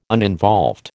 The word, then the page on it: uninvolved: American English